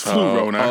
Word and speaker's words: Flurona: F-L-U, so it's Flurona.